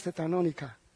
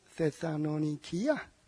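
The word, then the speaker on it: Thessalonikia (Θεσσαλονίκη): Did I tell you that actually they don't pronounce Thessalonica? They pronounce Thessalonikia.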